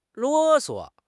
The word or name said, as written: 啰唆